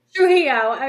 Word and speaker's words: Trujillo: Trujillo.